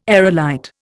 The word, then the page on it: aerolite: Indian